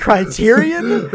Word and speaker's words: Criterion: Are you gonna fight us on if it's pronounced Criterion, which it clearly is? – Yeah, we're watching the Criterion Collection.